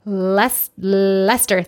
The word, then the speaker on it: Leicester: Lester, thank you. But it's spelled like Leicester. Why don't you pronounce the middle syllable? Lester. – I'm seeing Leicester, and then like, Leicester? Nope, it's just Lester.